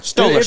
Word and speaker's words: Stoller: Is that Stoller? – Stoller. – It's like Stroller, like you're pushing a stroller.